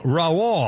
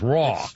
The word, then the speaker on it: Roy: And Patrick Raoul, I pronounce Raoul, it's spelled Roy, R-O-Y. Patrick Raoul, how do you pronounce it? Raoul? Patrick Raoul, the Hockey Hall of Famer, 61. – Raw. Patrick Raw.